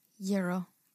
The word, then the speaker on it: gyro: Y-E-E, euro. – It's a gyro. It's a gyro. – It's a gyro on Long Island. I know, people like manipulated me. They're like, you're saying it wrong. And now I feel like I should say euro, but gyro is just how we say it, yeah.